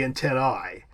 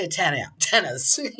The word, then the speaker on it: antennae: In Latin, the correct pronunciation of an A-E diphthong would be an I, so it'd be antennae. However, in English, it's pronounced? – Antennae.